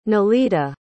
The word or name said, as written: nelida